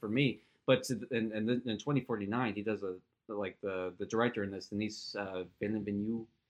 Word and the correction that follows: Denis Villeneuve: Is that how you say it? I thought it was Denis Villeneuve. – If I mispronounce it's Denis Villeneuve, I'm pretty sure.